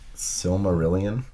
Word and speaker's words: Silmarillion: Silmarillion. – Would you got it from Tom Bombadil? – No. – I would have gotten it from Silmarillion. Yeah. – Silmarillion. – If someone had said Silmarillion.